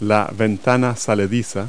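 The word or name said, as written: bay window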